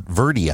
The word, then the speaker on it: Verde: Everybody here calls it Verde, apparently, and we've been saying it wrong.